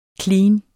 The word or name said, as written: clean